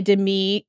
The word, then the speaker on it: Demi: played by Demi, accent on the second syllable, Moore.